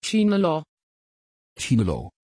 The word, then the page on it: chinelo: Dutch